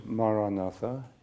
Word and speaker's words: Maranatha: If you choose this word, Maranatha, say it as four syllables, Ma-ra-na-tha.